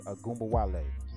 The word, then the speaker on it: Ogunbowale: You got Arike I always say her name wrong. Ogunbowale. I got it. Arike Ogunbowale.